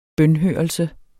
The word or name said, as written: bønhørelse